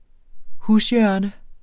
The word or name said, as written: hushjørne